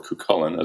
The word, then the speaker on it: Cuchulain: Yeats and tuning in, will know Cuchulain, or Cuchulain, as it's sometimes pronounced, as this ancient mythological Irish hero, like this symbol of Irish nationalist pride and strength.